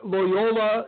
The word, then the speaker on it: Loyola: I have Michigan defeating Loyola Chicago. Oh, got it again. – Oh, you're two for two, what? Baby. I, too, have Michigan going over Loyola, Illinois.